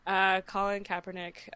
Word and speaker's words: Colin Kaepernick: The Colin Kaepernick. – Sorry. Colin Kaepernick.